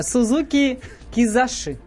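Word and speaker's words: Suzuki Kizashi: Сузуки Кизаши. Правильно я произношу? – Правильно.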